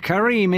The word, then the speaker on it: Karim: It's Yusuf with a Y. Y-U-S-U-F. And Karim is K-A-R-I-M. – R-I-M.